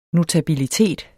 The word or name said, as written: notabilitet